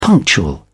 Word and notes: punctual: British English

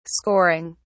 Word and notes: scoring: British English

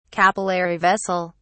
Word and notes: capillary vessel: British English

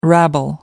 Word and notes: rabble: American English

